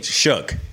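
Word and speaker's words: Shough: I did say his name right. – Okay. – Shuck. – Shuck. – Allegedly. I need a pronunciation guide. – You're an Oregon Duck. You can say UCK.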